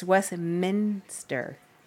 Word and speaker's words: Westminster: Westminster drives me fucking crazy. It's Westminster. There's no minister. Westminster.